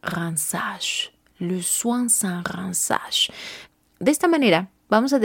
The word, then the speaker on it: rinçage: Ahora, la palabra ransage también quiere decir aclarado.